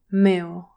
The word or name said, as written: mill